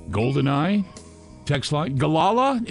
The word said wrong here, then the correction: Galaga: Is that my saying that right? – Galaga. – Galaga.